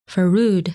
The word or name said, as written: Furud